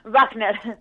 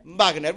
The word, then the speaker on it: Wajner: Wagner. Wagner.